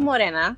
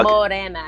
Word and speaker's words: Morena: Okay, so it's called Morena. Um, okay, Mo- Mo- okay. Mo- Morena. Morena, yes.